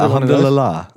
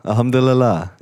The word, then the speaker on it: Alhamdulillah: Is that the Alhamdulillah? – Yeah, Alhamdulillah. Alhamdulillah. Yeah. – Yeah. – That is how you say it.